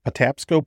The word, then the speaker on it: Patapsco: Patapsco Park.